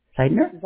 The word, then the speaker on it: Zeidner: Z-E-I-D-N-E-R. – Oh, wow. How do you pronounce that? Zeidner? – Zeidner.